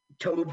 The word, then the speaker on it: Tobe: Toby Hooper, am I saying it wrong? – No, I think you're right. I think it is two syllables. I probably pronounced it Tobe. Tobe Hooper. Tobe. – Like a tough road.